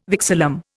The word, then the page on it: vexillum: Indian